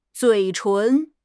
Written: 嘴唇

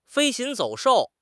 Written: 飞禽走兽

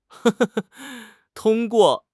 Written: tong1 guo4

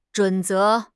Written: 准则

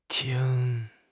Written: qing1

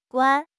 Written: guan1

